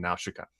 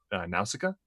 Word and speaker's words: Nausicaa: But Nausicaa in the Valley of the Wind, I don't know, how, like, because in the Japanese version, they pronounce her name Nausicaa. In English, they pronounce it Nausicaa.